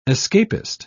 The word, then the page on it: escapist: British English